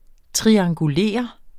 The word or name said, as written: triangulere